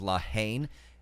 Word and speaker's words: La Haine: Which is La Haine, another French film, commonly mispronounced as La Haine. It means hatred.